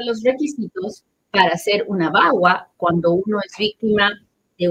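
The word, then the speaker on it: VAWA: Escríbame, por favorcito, agarre su teléfono y escríbame de qué ley estamos hablando hoy día se escribe B chica A W A, Bagua.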